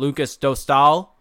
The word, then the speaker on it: Dostal: Lucas Dostal, there you go, John. Pronounced it correctly this time.